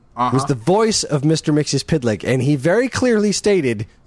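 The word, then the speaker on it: Mxyzptlk: It is pronounced Mixia's Pidlick. – Mixaplix, which is what I've always said and will continue to say.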